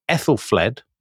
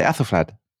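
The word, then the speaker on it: Æthelflæd: But you, for example, say Ethelfled. – No, I don't. I say Athelflad. – Oh, you say Athelflad, do you? – Athelflad. Because it's Ash. It's a letter, Anglo-Saxon letter, and it's pronounced Ah.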